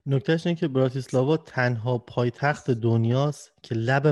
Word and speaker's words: براتیسلاوا: گفت نزدیک به وین، پایتخت اسلواکی براتیسلاوا، درسته؟ تلفظش؟ – بله براتیسلاواست. در واقع آره.